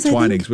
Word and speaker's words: Twinings: Twinnings or Twinings? I don't know. – Okay. – Twinnings. Which one is it? – Twinings.